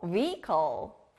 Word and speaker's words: vehicle: 여기도 역시 H가 들어갔지만 아무 소리가 안 나요. 우리가 이동할 때는 차량을 타죠. 차량, 영어로 vehicle.